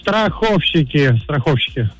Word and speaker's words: страховщик: Но словари это желание не поддерживают и фиксируют единственно верный вариант произношения с ударным вторым слогом, то есть с акцентом на букву «о», так что правильно говорить «Страховщик!» Вячеслав, молодец!